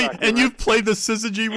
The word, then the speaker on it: syzygy: There's a word that's associated with eclipses I always love, and that's syzygy. – Yes. – Is that the correct pronunciation? – Yes,